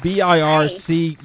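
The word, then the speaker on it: BIRCWH: All right, what is BIRC? Hi. You caught me off guard here with this large acronym. What does that mean? – Sure. It's actually pronounced BIRCH.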